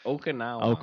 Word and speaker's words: Okinawa: Or Okinawa. – Okwa. – Now, if you remember Karate Kid 2, right? – It was 2. – Okinawa.